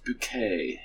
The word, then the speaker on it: Bucket: And their last name is Bucket, but she says, it's pronounced Bouquet.